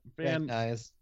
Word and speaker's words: Van Nuys: Van Nuys.